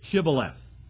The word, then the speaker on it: Shibboleth: They're getting ready to cross the Jordan River, but the word in Hebrew was Shibboleth. But the men of Ephraim couldn't pronounce an SH.